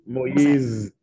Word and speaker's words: Moise: So speaking still on the topic of presidents, I may need your help pronouncing this one as well. the former president, Jovenel. – Jovenel. – Jovenel Moise? – Moise. – Moise, yes. Okay.